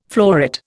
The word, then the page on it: floret: Indian